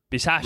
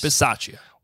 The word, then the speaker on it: Bisaccia: Bisaccia. – Rich, yes. – Bisaccia.